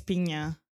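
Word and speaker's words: Pinho: But she is the Is it NH? – NH, yeah. – I think it's Pinha. – Pinha, okay. – It's like a NY kind of thing, I think.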